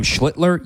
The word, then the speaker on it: Schlittler: You're going to see, I mean, one of the surprises of the season, Cam Schlittler. Yes, that's how you say his name. Schlittler, emphasis on the L's, so you don't get canceled.